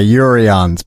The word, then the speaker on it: Juryens: The J is actually pronounced like a Y, Juryens, but Juryens. I always let people go with the Juryens because it's so complicated.